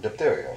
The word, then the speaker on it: diphtheria: The first syllable is dip. – Oh, diphtheria.